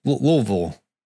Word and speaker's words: Louisville: Louisville.